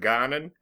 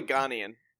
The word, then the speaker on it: Ghanaian: Ghanaian? What do you? Was it Ghanan? – No. I think you're right. – Is it? – Or no? It's probably Ghanaian.